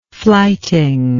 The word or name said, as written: fliting